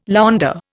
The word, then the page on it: Länder: Indian